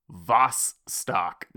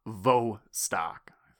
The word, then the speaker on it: Vostok: I'm not going to name the user, but he said, "Repeat after me, Vostok, not Vostok."